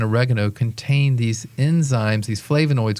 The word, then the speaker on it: flavonoids: Well, I read it as good news, and it's about the impact that some of the flavonoids, and I might mispronounce them. – No, that's exactly right.